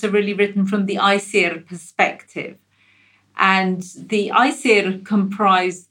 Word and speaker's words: Æsir: Very quickly, you've got your Æsir,